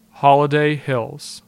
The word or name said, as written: Holiday Hills